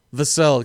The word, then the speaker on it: Vassell: Devin Vassell, Vassell, excuse me. It's spelled just like Sam Cassell, but with a V.